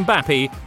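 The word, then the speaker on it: Mbappé: Today, why Kylian Mbappe rhymes with unhappy, and does he no longer want Neymar as his neighbour?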